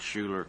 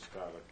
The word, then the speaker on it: Schuyler: I think it was Schuyler County.